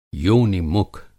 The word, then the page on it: योनि-मुख: Hindi